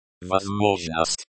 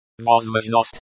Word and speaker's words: Возможность: Возможность. Возможность. Stress on the second syllable. The O sound is like the O in hot.